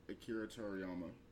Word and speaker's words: Akira Toriyama: Some people feel as though, with Akira Toriyama, I might say the name wrong. – No, you said it right. Akira okay.